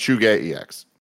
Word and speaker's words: ZhugeEX: It's pronounced Chuge EX. – Oh, wow. – Yeah, Chuge it's, EX. It's a Chinese thing.